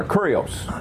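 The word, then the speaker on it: kurios (κύριος): You see that letter that looks like a P? They pronounce it R. They'll pronounce it like an R, kurios.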